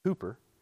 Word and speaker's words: Cowper: His last name actually looks like Cowper, but it's pronounced Cooper.